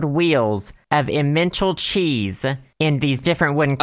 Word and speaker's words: Emmental: It's pronounced Emmental.